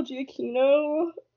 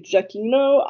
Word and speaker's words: Giacchino: And then Michael Giacchino, I'm pronouncing that the Italian way, actually be Giacchino. I don't know if he pronounces it that way, but we'll go with it.